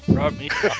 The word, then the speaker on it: Romita: Geoff Isherwood e o John Rom John Rob John Ho como é que pronuncia, gente? Romita.